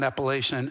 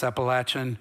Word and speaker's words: Appalachian: And that is how you say it. It's not Appalachian, it's Appalachian. I'm a hillbilly.